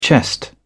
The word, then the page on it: chest: British English